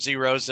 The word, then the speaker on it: Xero: I mean, yeah, I pronounce them zeros. They're X-E-R-O. – X-E-R-O.